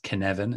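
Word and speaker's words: Cynefin: It's Kinevin, it's how it's pronounced. I'm not Welsh, but that is how you pronounce it. The guy who made it is very passionate to pronounce it properly.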